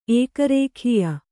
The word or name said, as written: ಏಕರೇಖೀಯ